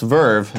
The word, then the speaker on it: VRV: Verve, VRV is pronounced Verve, made by the team over at Crunchyroll, is home to your favorite channels.